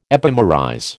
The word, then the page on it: epimerize: American English